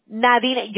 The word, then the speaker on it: Nadine: Que no se puede pronunciar en español. En inglés ella se dice Nadine.